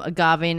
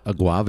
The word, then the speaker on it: agave: It had some agave nectar. We reduced the amount of agave nectar. – Or and as I call it, aguave. – Aguave. This man. – Another one of my favorite mispronunciations.